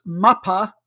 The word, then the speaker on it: MAPA: And the show that he is on YouTube is once again called This Is MAPA, and I hope I'm getting enough of a break between the two syllables that I'm a little bit closer to the Cypriot Greek pronunciation.